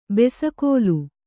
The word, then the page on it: ಬೆಸಕೋಲು: Kannada